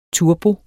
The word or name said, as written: turbo